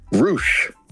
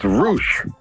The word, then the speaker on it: Rush: You said Rush? – Roosh. – How you spell that? – R-U-S-H. – Yeah, isn't that Leo Rush? – No, it's Roosh from Mexico.